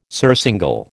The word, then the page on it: surcingle: American English